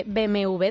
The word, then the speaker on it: BMW: La marca tiene mucha culpa de que nosotros digamos BMW en vez de BMW doble. – Claro. – ¿Pero por qué? Bueno, pues porque la W en alemán se pronuncia así. – BMW. – Se pronuncia como la V.